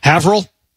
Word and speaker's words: Haverhill: H-A-V-E-R. – H-I-L-L. Just go ahead and say it. – I say Havril. – Try it again. – Havril. – You're close. – Okay. – Long A.